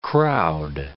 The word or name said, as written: crowd